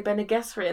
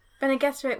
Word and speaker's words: Bene Gesserit: Oh, I've been listening to too many podcasts who say Bene Gesserit in the way that I don't like. – Bene Gesserit with a hard G? – Yeah.